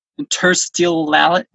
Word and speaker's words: interstitiality: Liminality, interstitiality, Is that it? Interstitiality? I can't pronounce it. Oh. Transitional space. – Interstitiality? – Yeah, interstitiality. – Thank you.